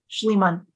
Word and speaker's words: Schliemann: Schliemann.